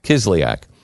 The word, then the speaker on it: Kislyak: Also, Kushner had spoken with Sergey, how do you say his, Kislyak. – Manafort had spoken to him in person at the Mayflower Hotel. Kushner would have another call with Kislyak, meeting him the Russian or Putin's banker.